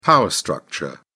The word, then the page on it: power structure: British English